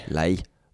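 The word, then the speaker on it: Ley: Lee, hoe zou je dat uitspreken? – Lei.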